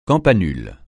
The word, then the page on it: campanule: francais